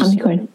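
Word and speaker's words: Omnichord: It was the same sort of go with the Omnicord. Did I pronounce that correctly? The instrument that you use, which is Omnicord.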